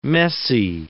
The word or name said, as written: messy